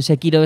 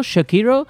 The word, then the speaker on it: Sekiro: Los japoneses hacen una palabra que a la vez es esdrújula y llana, que sería Sekiro o algo así parecido, que me hace incidencia en las dos sílabas, y entonces nosotros no la pronunciaremos bien nunca si la pronunciamos en castellano.